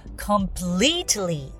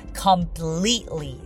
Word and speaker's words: completely: これも completely と t を発音する方もいらっしゃいますけれども、私は completely はい。